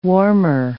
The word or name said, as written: warmer